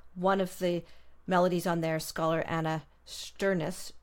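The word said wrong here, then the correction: Sternschuss: no, I'm not pronouncing that right, Sternschiss. – That's right. That's right.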